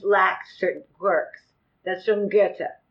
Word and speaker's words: Goethe: Goethe. It's German. – See it? I would have said Goethe. – I was going to say Goethe.